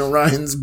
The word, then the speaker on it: Orion: You'll be seeing UFOs in Orion's bungus. – You'll become Gary Sudbrink. – You'll be looking at Orion's. Orion's anus. – Oh, Orion. That's not how you say it.